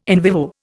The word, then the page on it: in vivo: Indian